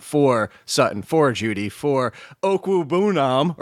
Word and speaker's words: Okwuebunam: Okwuebunam. – Okwuebunam. I don't think the ooh is Okwuebunam. It's only four syllables.